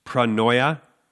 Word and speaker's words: pronoia: It's pranoia, which means forethought. It rhymes with metanoia, which is the word for repentance.